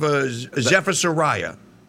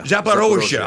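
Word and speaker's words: Zaporizhzhia: That's above Zaporizhia. – Zaporizhia. – I always mispronounce Zaporizhia. Yeah. Thank you.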